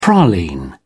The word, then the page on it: praline: British English